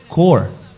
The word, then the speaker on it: Corps: Se debe decir Core. Uh-huh. Y se escribe C-O-R-P-S.